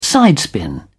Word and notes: sidespin: British English

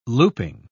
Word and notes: looping: British English

